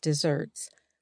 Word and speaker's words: deserts: These critiques invoke the ancient concept of just deserts, pronounced like desserts, or people getting the punishment they deserve, which is a cornerstone of how judicial systems try to obtain justice.